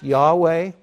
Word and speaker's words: Yahweh: L-O-R-D, Lord, normally that's the translation of Y-H-W-H, of the Hebrew Y-H-W-H, which we would pronounce Yahweh.